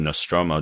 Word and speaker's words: Nostromo: In the Alien trilogy, she was not only tough, being the only one to survive the Nostromo.